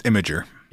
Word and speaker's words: Imgur: In November of 2013, on the website Imgur, is it pronounced Imgur or is it Imgur? – It's Imgur. – It is Imgur.